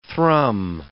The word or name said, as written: thrum